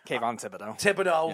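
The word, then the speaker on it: Kayvon Thibodeaux: Kayvon Thibodeau.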